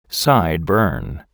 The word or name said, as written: sideburn